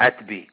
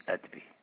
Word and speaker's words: Atbi: Atbi, Atbi.